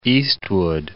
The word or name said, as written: Eastwood